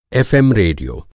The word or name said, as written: रेडियो